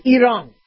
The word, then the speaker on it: Iran: We don't say Iran. We say Iran. We've been saying Iran for years. But Iranians don't like it. It's pronounced Iran.